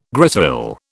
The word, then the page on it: grisaille: British English